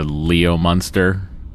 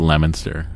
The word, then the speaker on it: Leominster: It's like when people from not Massachusetts try to pronounce Massachusetts towns, and they call like Leominster Leo Munster. Uh-huh. And it's just, no, it's Leominster.